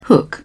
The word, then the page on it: hook: British English